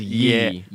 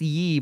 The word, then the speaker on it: Ye: Yee or Ye? I haven't heard it. I've just seen Yee. – I don't know the proper pronunciation. – I feel like it's Yee, but also I could be wrong. – Yeah, it's Yee, but then that just makes me want to say, oh yeah, look, it's Yee.